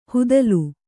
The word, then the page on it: ಹುದಲು: hudalu